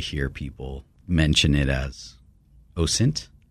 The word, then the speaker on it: OSINT: It's OSINT with a soft S.